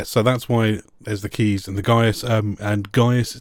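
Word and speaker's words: Caius: which is actually because there's a college in Cambridge where I'm from called Gonville and Keys, and it's spelled Gonville and Gaius.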